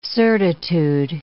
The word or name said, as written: certitude